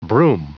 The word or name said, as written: broom